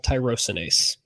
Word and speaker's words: tyrosinase: The gene is called tyrosinase.